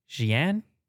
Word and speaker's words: Xi'an: The promotion was in, how would you pronounce X-I-A-N? Xi'an? – Yeah. – Did I nail that? Xi'an? – I think so.